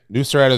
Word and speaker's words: Nusret: Nusret is a Turkish chef.